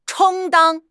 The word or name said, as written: 充当